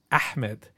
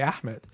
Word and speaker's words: Ahmed: And they Arab, like, you know, in high school and middle school, I would meet some Arab kids who would tell me that I was saying my name the wrong way. They'd be like, actually, your name is Ahmed.